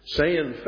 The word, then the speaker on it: Seine: This is commonly known as Seine fishing, S-I-E-N-E, but it's pronounced Seine, like say with an N on the end. And it's from the Latin word sagina, which means a draw net fishing. Seine fishing is a very ancient kind of fishing.